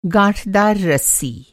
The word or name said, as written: गाँठदार